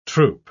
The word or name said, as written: troop